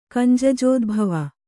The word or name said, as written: ಕಂಜಜೋದ್ಭವ